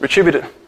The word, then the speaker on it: retributive: It shows itself in retributive justice. But Jesus Christ has shielded us from the nightmare prospect of retributive justice. Retributive. How do you say that? Is it retributive? Retributive. Retributive. That's it.